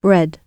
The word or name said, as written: bread